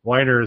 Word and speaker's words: Weiner: Matthew Weiner is how his name is pronounced. A guy who writes Mad Men.